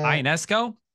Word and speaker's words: Ionescu: Ionescu,